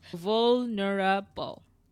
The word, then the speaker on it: vulnerable: I made four syllables here. One, yes. two, three, four. – Vulnerable. okay. There you go. Vulnerable. – Yeah, there you go. Keep the vol as one.